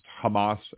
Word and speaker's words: Hamas: Donald Trump mispronounced Hamas as hummus,